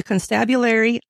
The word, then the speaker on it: constabulary: As the constabulary, I said it right that time. – Yeah, you did.